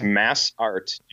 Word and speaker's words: Massart: It's Massart. It's like, it's two words, basically.